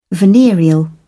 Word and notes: venereal: British English

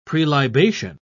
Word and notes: prelibation: British English

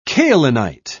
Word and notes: kaolinite: British English

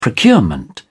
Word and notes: procurement: British English

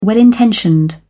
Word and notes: well intentioned: British English

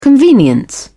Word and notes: convenience: British English

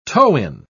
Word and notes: toe-in: British English

